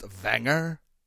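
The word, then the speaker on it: Vanger: Actually, it's pronounced Wanger.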